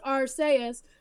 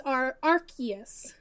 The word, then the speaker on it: Arceus: There's two pronunciations. – No! – In the trailer for our whatever his name is in the Duel of Lights, it's pronounced Arceus, but the official pronunciation is Arceus.